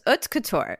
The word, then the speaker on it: haute couture: Haute couture. So haute couture. – You don't really pronounce age.